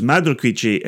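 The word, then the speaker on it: Madre Cuishe: How does that SH? Because what's Madre Cuiche? – Quiche. – Okay, so there's Madre Quiche and there's Be Quiche.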